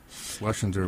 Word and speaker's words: Schlesinger: Dr. Laura Schleislinger. – Oh, yes, I heard about this. – Yeah, how do you pronounce it? Schlesinger? – Schlesinger would be right.